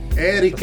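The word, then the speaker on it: Éric: Eric ed Eric, perché l'accento è importante.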